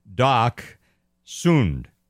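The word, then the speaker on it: Dachshund: Doc Sund. That is, that is the German.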